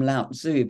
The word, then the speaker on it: Lao Tzu: And Lao Tzu, I always called him Lao Tzu, but I was listening to how the Chinese pronounce his name, and it's Lao Tzu.